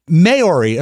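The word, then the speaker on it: Māori: Is that Maori or Maori? – Maori, according to the pronunciation.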